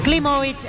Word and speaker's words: Klimovic: Está bien pronunciado el apellido. – Klimovic, exactamente, como si fuera con B corta.